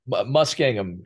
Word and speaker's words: Muskingum: Now, help me pronounce it. Is it Muskingum or Muskingum? – Muskingum. Yeah, Muskingum.